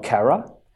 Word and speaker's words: CARAT: And the agency I work for is called CARAT. And we, C-A-R-A-T though, but the T is silent.